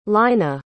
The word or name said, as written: lynna